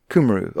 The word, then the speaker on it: Cymru: it's like C-Y-M-R-U or something like that, which means like Cymru or something like that. – Cymru. – Okay, yeah. So the Y has a ooh sound. – But in the name Rhys, which is spelled R-H-Y-S, it has an E sound.